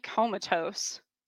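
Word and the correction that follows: Camazotz: but that which would actually be relevant later, but that's not how you pronounce it. Comazots.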